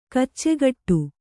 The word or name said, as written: ಕಚ್ಚೆಗಟ್ಟು